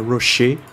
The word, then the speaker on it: Roche: É, e confirmando, Ricardo, realmente a pronúncia do cara é Rocher. – Quincy Rocher. Belo nome, belo nome. E... É, é só pensar no chocolate.